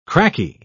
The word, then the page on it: cracky: British English